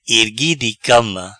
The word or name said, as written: Ergui di cama